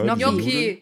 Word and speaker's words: Gnocchi: Wie spricht man das aus? Gnocchi, ne? – Gnocchi.